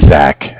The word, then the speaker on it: Zach: My name is Zach, spelled Z-A-C-H. – With? – Oh, I'm sorry. I'm with Cardiff. It's spelled C-A-R as in car, D-I-F-F as in different, like Cardiff, Wales in England.